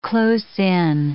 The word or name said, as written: close-in